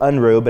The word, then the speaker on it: Unruh: home of Unruh, or it pronounced it Unruh, but it's U-N-R-U-H, furniture, and Solomon's Porch met there for a while.